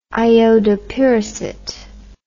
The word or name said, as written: iodopyracet